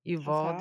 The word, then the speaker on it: Uvalde: Salvador Ramos, 18 years old, used an AR-15 style semi-automatic rifle in the bloodshed Tuesday at Robb Elementary School in Uvalde. Is that how you pronounce it? Uvalde. – That's how I pronounce it.